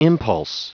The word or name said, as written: impulse